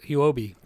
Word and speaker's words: Huobi: You can find it on major oh majority of their volume is Hubi Hubi Huobi. God, I always say that wrong.